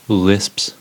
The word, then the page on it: lisps: American English